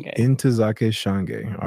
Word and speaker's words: Ntozake Shange: Ntozake Shange.